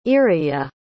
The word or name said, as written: iraia